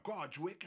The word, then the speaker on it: Kortrijk: Faces du quintet Balthazar, qui nous vient de Kortrijk en Belgique, mais qui se prononce Kortrijk en flamand.